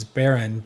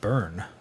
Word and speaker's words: Beren: And you're pronouncing it correctly, which is Beren, but I never said Beren. I said Bern.